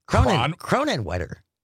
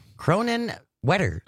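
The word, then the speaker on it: Kronenwetter: Julianne and Cronwetter. Cronenwetter. – That doesn't feel like a place. – Cronenwetter. – Spell that for me. – K-R. – Oops. K-R. – O-N-E-N-W-E-T-T-E-R.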